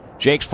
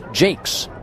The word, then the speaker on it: Jacques: Spelled Jacques, but they tell us in the pronunciation guide for Indianapolis, it is pronounced Jakes. And so I will believe them. J-A-C-Q-U-E-S.